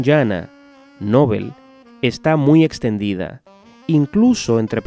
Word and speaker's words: Nobel: Yo me apego a la forma llana, Nobel, tanto por la fuerza de la costumbre como por el hecho de que así evitamos confundirla con novel.